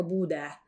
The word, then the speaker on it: Bodø: A Budę, bo każdy się pewnie zastanawia, jak to się pisze, pisze się B-O-D-O z taką kreską, która przechodzi przez O. I to czyta się jako Budę.